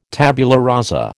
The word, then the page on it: tabula rasa: American English